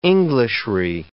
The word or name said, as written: Englishry